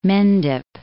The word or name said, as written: Mendip